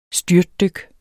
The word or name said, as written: styrtdyk